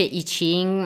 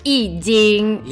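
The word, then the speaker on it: Yijing: E poi ricordiamo che non si dice I Ching, ma si dice I Jing.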